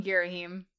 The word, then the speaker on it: Girahim: No, I'm also on Team Girahim.